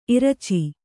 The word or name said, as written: ಇರಚಿ